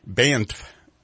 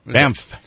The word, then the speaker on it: Banff: Today on the show, of course, we're going to be talking to Banff Mountain, Banff. I'm not even pronouncing it right. – Banff.